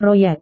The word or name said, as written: proyek